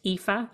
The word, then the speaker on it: Aoife: It's spelled Aoife and it's pronounced Aoife. And my surname is O'Brien, O-B-R-I-E-N.